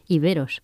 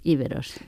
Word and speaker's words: iberos: Y ellos hablaban de iberes, es decir, ponían el acento en la E central, que era una eta y no una épsilon. Por eso deberíamos pronunciar iberos, pero lo normal es que digamos íberos.